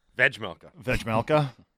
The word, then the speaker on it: Vejmelka: They signed Vemelka. – Vejmelka. It's a hard J.